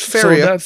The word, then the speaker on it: Faeria: And the only last thing I want to mention isn't a tabletop game, but I've been playing Faeria. That's how I would pronounce this in French. Pretty similar in English. – Faeria.